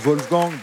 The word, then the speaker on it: Wolfgang: Oui, Wolfgang, on prononce Wolfgang, parce que c'était de langue allemande, mais c'est bon.